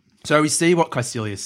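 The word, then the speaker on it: Kaecilius: Caecilius, yeah.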